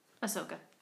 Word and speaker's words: Ahsoka: Um, Ahsoka returns to the camp and tells Omer and Jinx what happened. You didn't spell it wrong. I just said it wrong. – What? Ahsoka. Oh, yeah.